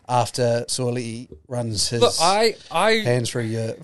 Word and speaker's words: Sua'ali'i: Sua'ali'i. I believe. – Yeah, so. I think, because I pronounced it in a YouTube video and someone called me out for it. So Sua'ali'i.